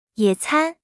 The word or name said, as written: 野餐